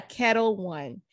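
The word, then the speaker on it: Ketel One: Am I pronouncing that right? – Kettle One? – Yeah, Kettle One.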